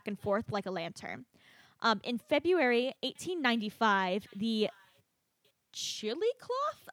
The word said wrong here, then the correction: Chillicothe: Chillicothe. – Chillicothe? – Oh, that was such a cute pronunciation. – Chillicloth? – chili cloth. – Chillicothe is how you pronounce that word.